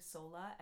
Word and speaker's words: Sola: Hi, my name is Megan, aka Sola, S-O-L-A, not Solar.